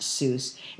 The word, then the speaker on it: Seuss: His personally pronounced Seuss is the German fashion rhymes with voice, though most readers mistakenly assume it rhymes with juice.